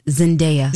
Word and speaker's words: Zendaya: It is pronounced Zendaya.